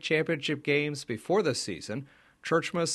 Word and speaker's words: Tjeerdsma: First things first. The last name pronunciation of the Bearcats head coach, spelled T-J-E-E-R-D-S-M-A, is Churchma. – It's a Dutch name. The Dutch, we were in Holland a couple of years ago, and the Dutch don't have any problem with it. They pronounce it Schiertsma, but it has been Americanized.